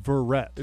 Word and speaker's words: Verrett: It's pronounced Verrett. – Is it Verrett? – I think it's Verrett.